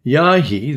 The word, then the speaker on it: Jaaiji: and Yahi, that's pronounced, yes, I just did. Yahi, it's spelled J-A-A-I-J-I.